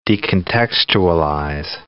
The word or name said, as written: decontextualize